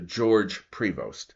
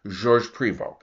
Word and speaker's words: Georges Prevost: Now, very quickly, you will still sometimes see on C-SPAN or PBS or on some website an historian, usually English, who ruthlessly anglicizes this man's name to Georges Prevost. But for over two centuries, it's been pronounced in Canada Georges Prevost,